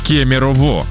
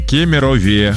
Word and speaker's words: Кемерове: В Кемерове на протяжении долгого времени говорили даже в СМИ «в Кемерово». А вот в последнее время стараются использовать правильную форму, но для большинства «в Кемерове» продолжает резать слух. Угу. Да, правильно говорить в городе Кемерове.